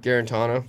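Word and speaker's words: Guarantano: See if Garantano – how do you say it? – Garantano.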